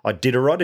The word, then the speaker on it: Iditarod: You know, the ultra endurance adventure, this 430-mile Yukon Arctic ultra marathon or ultra adventure trail or race, a 350-mile Iditarod, is that right? – Yeah, yeah.